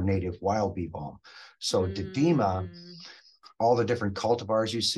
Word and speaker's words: didyma: So, Stephanie, if you go out to the eastern United States, let's stay upstate New York, there's another Monarda species called Monarda didyma. That is the one that most of the, and I think it's spelled D-I-D-Y-M-A. It's a funny one to pronounce, but didyma is also called Oswego tea.